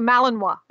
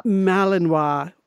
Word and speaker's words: Malinois: It's a Malinois. And that's how you pronounce it. I even looked it up. – Malinois.